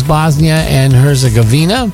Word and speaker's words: Bosnia and Herzegovina: In World Cup news tomorrow in the round of 32, the United States versus Bosnia and Herzegovina in the knockout round.